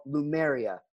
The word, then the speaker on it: Lumeria: Lumeria, and that's spelled L-U-M-E-R-I-A.